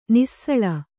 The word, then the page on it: ನುಸುಳು: Kannada